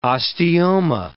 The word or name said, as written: osteoma